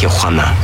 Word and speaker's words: Tijuana: It's pronounced Tijuana.